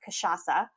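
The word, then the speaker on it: cachaça: So it's traditionally, it's the real spirit spelled with a C, C-A-C-H-A-C-A, and it's pronounced Kachacha.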